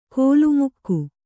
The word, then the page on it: ಕೋಲುಮುಕ್ಕು: kōlu mukku; kannada